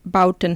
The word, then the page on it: built: german